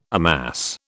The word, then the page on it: amass: British English